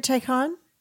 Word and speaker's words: Qigong: So you said iron shirt qigong.